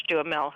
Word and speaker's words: Duhamel: But the reason to see it, if you want to go, is to see Josh Duhamel, who just hits it every moment in the movie. – Pronounce his name again for me. – Duhamel. – Duhamel. – Duhamel, yeah.